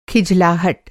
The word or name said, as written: खिझलाहट